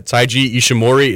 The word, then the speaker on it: Taiji Ishimori: Is that how you say it? Yeah, Taiji Ishimori is now the Bone Soldier.